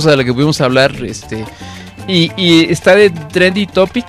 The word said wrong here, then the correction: trending topic: ¿lo dije bien? – Trending topics.